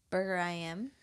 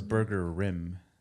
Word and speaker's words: Burger I Am: I thought it was Burger Rim. – No, there's no R in there. I mean, the I Am are capital, so it's separate.